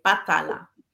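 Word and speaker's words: Batalá: So going forward, you've got your exciting, but is it Batala? – Batala. There's an accent on the A. Batala.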